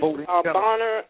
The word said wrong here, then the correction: Boehner: Did I pronounce his name right? – Yeah, the Speaker of the House, Speaker of the House, Boehner, John Boehner. – Boehner. Thank you for correcting me. Boehner, Mr. Boehner.